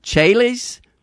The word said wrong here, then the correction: Chehalis: Washington, listen on Sirius XM 130. – Yeah, it's Chehalis.